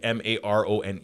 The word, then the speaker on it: Marone: It's spelled with a as like M A R O N E. – Yeah. – Pronounced Maron. Because when I heard it,